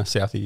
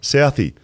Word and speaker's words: Southee: Say his name, Southee or Southee? – No, Southee, you got it right. – Southee.